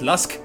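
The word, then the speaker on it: LUSQ: Really gorgeous, nice down-tempo piece of chill out from Sebastian Davison called Daybreak, which features Lusk, or L-U-S-Q. I think that's pronounced Lusk.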